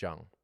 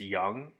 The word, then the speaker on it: Jung: Is it Young or is it pronounced Jung? – Depending on the expert or analyst, it's Young. – Okay. – But I have heard Jung, but Young is more commonly used.